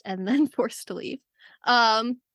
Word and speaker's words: Kyuss: K-Y-U-S-S is how you spell it. – Kyus. – Kyus. What do you say? Kyus.